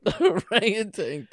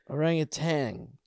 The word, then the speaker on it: orangutan: I'm trying to orangutan tits. – Oh, right. – Orangutan, right? Tan? I always put a G at the end.